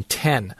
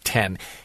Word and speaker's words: Then: And if you go click on it, you'll find that Juan Ten has signed to a minor league deal. T-H-E-N. That's how you spell his last name, but it is pronounced Ten. He was with the Mariners last year, and you know it's Ten.